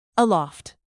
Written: aloft

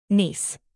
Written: niece

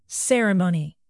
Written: ceremony

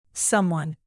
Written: someone

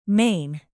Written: maine

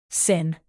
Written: sin

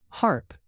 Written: harp

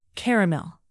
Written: caramel

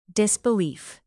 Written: disbelief

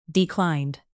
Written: declined

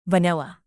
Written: vanilla

